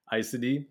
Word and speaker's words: Aes Sedai: So give that pronunciation to me one more time. Aes Sedai. Aes Sedai. I'm saying that right now.